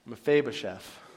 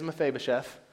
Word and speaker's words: Mephibosheth: I'm going to say it one time for you. Ready for this? It's the pressure. Mephibosheth. You're welcome. That's exactly how you say it.